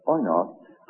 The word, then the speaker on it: oinos: It's come through into the Greek language, where it's pronounced as oinos, and it's worked its way right through into English, where it is pronounced as wine.